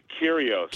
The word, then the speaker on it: Kyrgios: Nick Kyrgios.